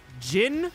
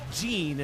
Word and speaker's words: Jin: Yeah, his name is like J-I-N. Do you pronounce it Jin or Jean? – Jin. – I've heard people say Jean, and I'm like, is that right? Am I wrong? But I always thought it was Jin.